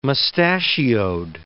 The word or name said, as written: mustachioed